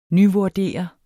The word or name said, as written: nyvurdere